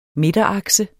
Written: midterakse

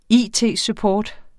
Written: it-support

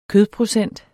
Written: kødprocent

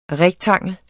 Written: REKTANGLERNES

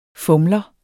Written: fumler

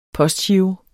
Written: postgiro

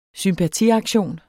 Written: sympatiaktion

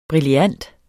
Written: brillant

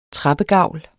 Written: trappegavl

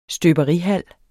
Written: støberihal

